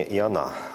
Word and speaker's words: Иона: Да, здесь все-таки с таким, с еврейским вариантом произношения Иона, ударение на А.